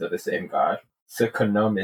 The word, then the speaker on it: Khnum: And Carter's like, well, so it's pronounced Khnum.